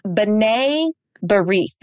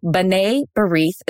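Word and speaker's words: B'nai B'rith: B'nai Barith. That's how you say it. Sorry, it's not spelled that way. So B'nai Barith is how you say it.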